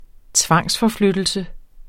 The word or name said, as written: tvangsforflyttelse